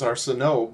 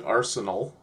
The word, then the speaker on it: Arsenault: The way they pronounce it, like, I would think it was Arsenault, but they pronounce it Arsenal.